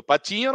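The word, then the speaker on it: Patiño: Mas se pronuncia é, igual um pato pequeno aqui. Você fala exatamente igual um pato pequeno. É patinho, isso.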